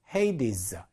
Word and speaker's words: Hades: 헤이디스 이렇게 읽습니다.